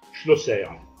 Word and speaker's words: Schlosser: Votre nom, comment, comment ça se prononce Schlosser.